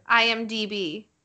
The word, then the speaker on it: IMDb: And I'm going to read the synopsis from IMDb. Did I do it right? – No, you didn't. – God damn it. – Say it again. – IMDb.